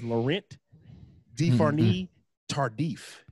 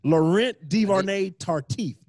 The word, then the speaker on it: Laurent Duvernay Tardif: Laurent Duvernay Tardif.